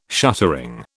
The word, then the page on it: shuttering: British English